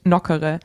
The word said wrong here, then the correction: Nokere: Nokere wird Nokere. Das ausgesprochen. – Nokere. – Nicht wie so ein Eis.